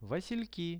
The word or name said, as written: vasilki